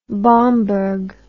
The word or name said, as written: Bomberg